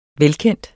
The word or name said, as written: velkendt